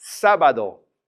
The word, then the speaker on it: sábado: It's three syllables. So normally we would stress that middle in sábado, but the accent comes in and says, I'm changing the rules. I want you to say the first syllable. It's stronger. So we're going to say sábado, because the accents are, they're in charge.